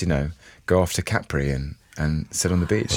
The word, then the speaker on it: Capri: I think it's Capri. – I think the Italians say Capri. – I suppose Capri. – With the accent on the A.